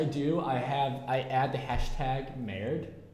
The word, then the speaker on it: merde: How many different ways did you try to pronounce the French word merde? Which means shit, by the way. – Well, I kept saying merde. – The worst one was Murdy.